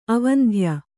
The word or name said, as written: ಅವಂಧ್ಯ